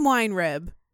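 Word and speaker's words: Weinrib: Because I totally did pronounce Getty's real last name wrong. – Oh, fuck. – It is Wine Rib.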